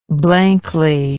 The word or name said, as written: blankly